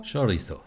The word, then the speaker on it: Chorizo: Chorizo. Chorizo. – Habe genau. ich das richtig ja, ausgesprochen? So. Ähm, wow. – Wow, wow, wow. Äh, muy bien. Muy bien, ja.